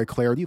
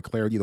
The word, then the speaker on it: Clardy: Give me the follow on Twitter, at Troy Clarity. The last name is spelled C-L-A-R-D-Y, at Troy Clarity, the way to follow me on Twitter.